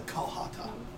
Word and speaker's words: Qalhata: There's an old legend about our land, about a ritual known as the Kalhata. Q-A-L-H-A-T-A. – Wow. If the DM tells you how to spell it, you better fucking write it down. Hold on. Q-U-A. – Q-A-L-H-A-T-A. The Kalhata.